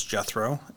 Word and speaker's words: Jethro: And what I really like about that example is that my name is Jethro, and even though it's pretty clear how to say it phonetically, it's some people still struggle with it.